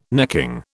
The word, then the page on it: necking: British English